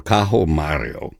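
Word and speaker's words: Caio Mario: Domenico Cimarosa's overture to his opera Cajo Mario, which is the Italian pronunciation of Gaius Marius, the Roman general and statesman.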